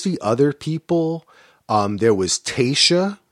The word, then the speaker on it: Tayshia: If I remember correctly, Tayshia wasn't, and I'm always worried about how I pronounce her name.